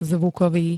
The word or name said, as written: zvukovy